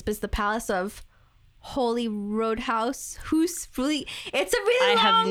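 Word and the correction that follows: Holyroodhouse: R-O-O-D Rude house? – House Holy Rood House.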